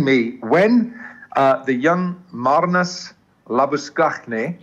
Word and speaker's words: Labuschagne: I'm going to say it like that because he's from the Free State. And he is Marnus Labuschagne. – Correct. – And I said to Harsha, I said, that's how you say it, Skakhne.